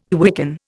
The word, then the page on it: quicken: Indian